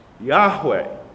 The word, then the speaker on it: Yahweh: Hebrew teachers and authors tell us that the name should be pronounced Yahweh.